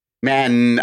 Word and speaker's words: Mann: Well, I yelled it because I'm excited, but I held the N because she has two N's in Mann. – You didn't hold the E's in Amy. – That's true. I should have. Amy Mann.